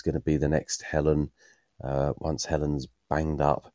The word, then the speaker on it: Helen: One thing I wanted to say was we've got a daughter called Helen, and perhaps it's because we're down south, but we call her Helen as it's spelt. But in the Archers, it always grates on me when people, especially Pat and Tony, call her Helen, as if it's her name spelled H-E-L-I-N.